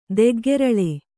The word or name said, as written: ದೆಗ್ಗೆರಳೆ